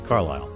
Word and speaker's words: Carlisle: You know, some people pronounce it Carlisle, but in her case, it's Carlisle.